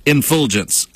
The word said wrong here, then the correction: effulgence: I'm sorry. Effulgence.